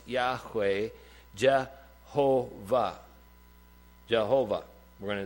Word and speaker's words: Yahweh: And traditionally, that has been suggested to, that you could pronounce that as Yahweh. Yahweh, YHWH.